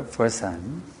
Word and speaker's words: Pforzheim: I have come tonight to your Pforzheim to deliver to you the translator.